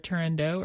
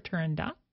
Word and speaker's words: Turandot: It can be pronounced either Turandot or Turandot. Depending on who you are and, you know, what you believe in terms of why those pronunciation happens.